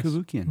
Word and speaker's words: Cavoukian: is it Cavoukian? Is that how you pronounce it? Cavoukian, yes. Cavoukian. Mm, you, you, did well.